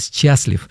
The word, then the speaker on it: счастлив: Ну, без «щ», конечно, вы понимаете, что я так произношу, вот, но пишется это с частью, да, вот, «счастлив».